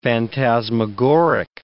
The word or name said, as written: phantasmagoric